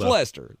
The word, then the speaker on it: Leicester: It's Leicester. That's how you say it.